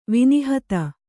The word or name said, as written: ವಿನಿಹತ